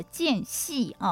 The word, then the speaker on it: 间隙: 那其实呢，在我们心理学来讲呢，有所谓的间隙哦，好，间呢就是那个时间的间哦，那念成四声哦，间隙哦，就是日常生活当中呢那些非预期的，而且是多出来的微小的碎片的时间。